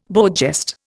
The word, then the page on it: beau geste: Indian